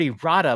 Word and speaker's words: errata: I was like, he hadn't heard of the word errata, but I was like, I don't think I've heard the word errata. Did I mispronounce it? And so I had to go to a dictionary, and I am pronouncing it correct. Errata. Sounds dirty.